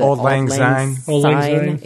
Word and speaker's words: Auld Lang Syne: Auld Lang Syne.